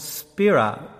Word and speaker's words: spira: And if you look up the Greek word for band, you come to the word which is called spira, S-P-I-R-A-H is how we would pronounce it.